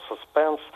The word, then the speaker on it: suspense: Это слово «suspense» от английского «suspense» – находиться в подвешенном состоянии, в состоянии беспокойства, в тревожном состоянии. Вот. Хотя, в отличие от других вот, слов этой категории, ударение на первом слоге здесь уже утвердилось.